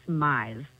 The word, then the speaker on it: Myers: It's Myers. – There you go. – Myers, as in like maximize, minimize, customize is what I always tell people.